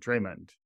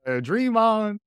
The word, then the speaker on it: Draymond: Draymond? I don't know why I pronounced it that way. Draymond.